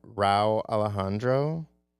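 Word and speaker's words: Rauw Alejandro: Rao Alejandro.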